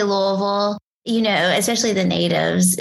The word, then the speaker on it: Louisville: So for starters here, what is the correct pronunciation of the town that you live in? – I say it as Louisville.